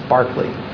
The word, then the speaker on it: Berkeley: So the first syllable is like, you know, a barking dog. It's Berkeley.